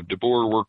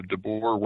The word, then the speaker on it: DeBoer: Yeah, so if you want the traditional Dutch or the American version, it doesn't really matter. But most people say DeBoer, like the hog. So DeBoer works well. – Okay. What's the Dutch version? – I've heard it is DeBoer, like would be two long O's. Mm. But most of the world says boer, so DeBoer works just fine.